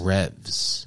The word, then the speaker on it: Revs: Revs. – Revs, is that how you say that? I always like thought it was like Reeves or something.